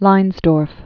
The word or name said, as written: Erich Leinsdorf